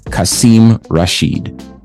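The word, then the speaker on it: Qasim Rashid: We want you to know the name, follow the social media, and support the candidate, Kasim Rashid. That is spelled Q-A-S-I-M, no U, Q-A-S-I-M-R-A-S-H-I-D. He is a Pakistani-born American author, an activist, and an attorney.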